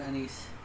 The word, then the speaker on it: anise: and last is anise. – I think you're saying it right. – Yeah, I think I am too. – Your niece? – A niece. Not a niece.